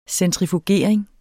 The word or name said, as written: centrifugering